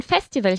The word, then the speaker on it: Festival: Irgendjemand hat uns gesagt, dass diesen Sommer ein Festival hier stattfindet, ¿vale? También lo pronunciamos a la inglesa, Festival.